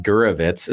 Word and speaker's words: Gurwicz: Ronnie, our friend, our new friend, Gurwicz. Is that our correct pronunciation? – Do you know what? Your pronunciation is way better than most people I've ever met.